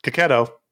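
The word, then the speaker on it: Checchetto: Hickman, Chichetto. Oh, Kaketo. – Oh, yeah. – It was Kaketo. The first opportunity I had to say it right. I did it wrong.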